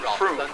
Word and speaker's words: Frome: The possibilities for misunderstanding are multiplied by the fact that it is spelled F-R-O-M-E. So to the uninitiated, this is quite clearly pronounced Frome, as in Rome, rather than Froome, as in Boom.